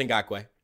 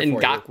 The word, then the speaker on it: Ngakoue: It's pronounced Ngakoue. – Man, I thought I was being slick there. – No, I got you. It's all right. Just ask for help. I'm here Ngakoue.